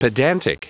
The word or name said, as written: pedantic